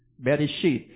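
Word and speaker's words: Bereshit (בראשית): It's Bereshet. How do you say it? Bereshit.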